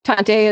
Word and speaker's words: Tante: Tante is, like, I gather it's a German word for auntie. – It is, yeah. – Yeah, yeah. Did I say it right? – Yeah.